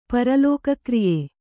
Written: ಪರಲೋಕಕ್ರಿಯೆ

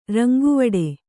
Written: ರಂಗುವಿಡಿ

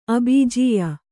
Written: ಅಬೀಜೀಯ

